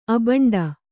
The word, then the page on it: ಅಬಂಡು: abaṇḍu